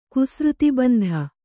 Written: ಕುಸೃತಿಬಂಧ

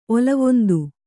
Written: ಒಲವೊಂದು

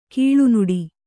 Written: ಕೀಳುನುಡಿ

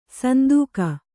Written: ಸಂದೇಗ

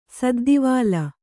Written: ಸದ್ದೀವಾಲ

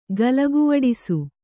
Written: ಗಲಗುವಡಿಸು

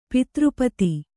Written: ಪತ್ರಿಪದ

